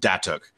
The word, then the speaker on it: Datuk: It is pronounced Datuk, and it's like an Italian wood finish, and it's really dramatic in its grain patterns.